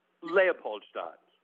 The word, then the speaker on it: Leopoldstadt: Le- I can't even pronounce it. Leopold. I you you say it. – Yeah, it's a tough one. Leopoldstadt. – Leopoldstadt. I'm going to I'm going to repeat that over and over.